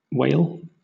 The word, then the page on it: wale: British English